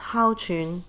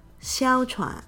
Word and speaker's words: 哮喘: In Cantonese, it's haochun. In Mandarin, xiaochuan.